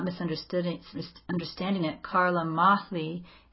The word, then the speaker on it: Mahle: It's not just Mel, is it? Carla Mel? Is that how you're saying we say her name? Mahle.